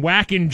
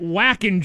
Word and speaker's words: Wacken: Wacken, Germany. Tara tweets us here. The German consonant W is pronounced like an English V. Get your German accent on. Also, it's not Wacken, Germany. It'd be Wacken, Germany.